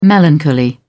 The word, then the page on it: melancholy: British English